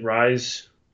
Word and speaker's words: Rhys: And I love Matthew Rice. Is that how you say it? Rice? R-H-Y-S? – Yeah.